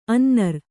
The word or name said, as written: ಅನ್ನರ್